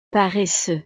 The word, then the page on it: paresseux: frances